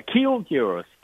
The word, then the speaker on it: Kiwoom Heroes: Okay, Kiwoom Heroes, right? Did I say that right? – Yeah, Kiwoom Heroes.